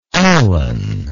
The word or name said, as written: Alan